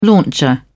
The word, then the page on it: launcher: British English